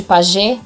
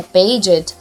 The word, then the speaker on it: Paget: Que também muitas pessoas é, falam doença de Paget, né? Mas a pronúncia correta seria Paget.